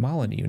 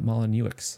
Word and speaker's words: Molyneux: It was created by the famous or infamous Peter Molyneux, which I watched a video of earlier, and you pronounce his name Molyneux, not Molyneux.